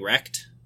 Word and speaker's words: RCT: My thought is I don't think he cares about inheriting Rekt, which, by the way, that's the pronunciation for RCT is Rekt.